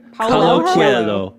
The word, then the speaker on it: Paolo Coelho: My pick was The Alchemist by Paolo Coelho. This is, did I say that right? – Paolo Coelho.